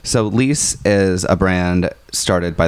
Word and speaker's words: Liis: L-I-I-S, pronounced Lies, I think. – Lies.